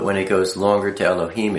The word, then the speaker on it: Elohim: Aleph, you can be pronounced Ah, E, and A. Lamed is pronounced L, the L. He is, the breath. Yod is pronounced E, and Mem is pronounced with an M. So, I mean, if you look at this, Aleph, Lamed, He, Yod, Mem. Al-ha-im. Elohim. Now, when you see just L, E-L, or sometimes L-E, E-L-I, then it becomes an A.